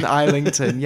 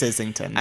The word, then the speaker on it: Islington: She was talking about London and she was talking about her friend who lived in Islington. – Oh. – Do you know where Islington is? – I do. Islington is just in North London. – Islington, yes.